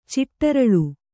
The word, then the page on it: ಚಿಟ್ಟರಳು: ciṭṭaraḷu